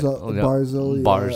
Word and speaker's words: Barzal: And then you've got, Barzal. Am I saying that right? – Barzal, Bailey.